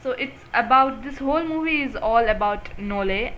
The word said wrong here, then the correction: Noelle: I think I'm pronouncing the word correctly, the name Noelle. – Yeah, Noelle. – Noelle. I thought there was an A in pronunciation. Noelle.